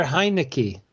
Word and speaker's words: Heinicke: It's Taylor Heinicke. Did I say his last name right? – Oh, yes. Yeah, that was good.